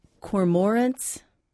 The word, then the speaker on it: cormorants: Alex writes, "Are cormorants, is that how I pronounce it? – Cormorants. – Cormorants, becoming a problem in central Ohio.